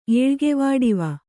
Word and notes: ಏಳ್ಗೆವಾಡಿವ: Kannada

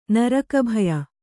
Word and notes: ನರಕಭಯ: Kannada

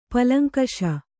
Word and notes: ಪಲಂಕಷ: palaŋkaṣa

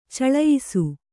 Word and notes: ಚಲಿಯಿಸು: Kannada

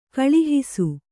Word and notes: ಕಳುಹಿಸು: kaluhisu

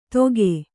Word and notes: ದೊಗೆ: Kannada

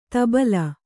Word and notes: ಧುಬಲಿ: kannada

